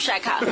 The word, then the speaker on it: Shaka: That is Tupac Shakur Zulu. but to be fair, people pronounce it differently. You know? – No, there is no version of Shaka.